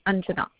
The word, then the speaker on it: Anjana: Anjana. – Sorry, can you spell it for me? – This is Anjana. A-N-J-A-N-A. Anjana. – Anjula. – Anjana.